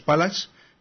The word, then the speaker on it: Phalax: Luego también hemos estado hablando de Linus Pallax, que se escribe PHALAX.